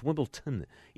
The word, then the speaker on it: Wimbledon: Now, I noticed that one of the big stories is that at Wimbledon, and by the way, it's pronounced Wimbledon, even though it's spelled with a D. It's pronounced like a T.